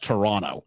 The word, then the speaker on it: Toronto: It's like back home, you can always tell if someone's an American because they won't say and back home, we slur Toronto, so it's Toronto. That last T doesn't really get said. It's Toronto. As opposed Sacramento. To an American will always hit that, Toronto. – Oh, really? – Or they'll go Montreal when a local would pronounce M-O-N like M-U-N, Montreal.